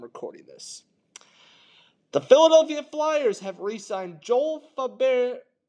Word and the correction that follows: Farabee: Far- Farabee I apologize that I said it wrong. It's Joel Farabee on a six year, $30 million deal.